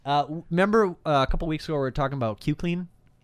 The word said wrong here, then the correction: Cook-Clean: It's actually pronounced cook, as in book, hook, or Q-Clean. Chef. – Cook-Clean. That's his handle.